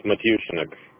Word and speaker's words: Matyushnok: It's Matyushnik. Let me spell it. It's MatyushnoK. – N-O-K? – N-O-K, yes.